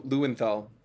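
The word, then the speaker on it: Löwenthal: So Leo Lewenthal, to use the anglicized version of pronouncing his name, or Lewenthal, flirted with Zionism in the early 1920s,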